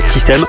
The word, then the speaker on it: Systaim: Alors, mis à part que ça s'écrit S-Y-S-T-A-I-M, System.